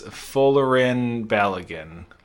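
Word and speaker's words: Folarin Balogun: That is from the United States, and it is Fullerin Balogun. I don't know if that's how you say his name. – It's close enough. It'll work. – Yeah.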